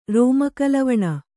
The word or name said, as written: ರೋಮಕಲವಣ